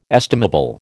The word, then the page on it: estimable: American English